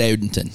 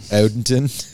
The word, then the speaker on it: Odenton: But yeah, I went to one brewery called the Crooked Crab in Odenton, although I'm not saying that right. – No, it's Odenton. – Odenton.